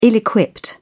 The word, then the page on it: ill-equipped: British English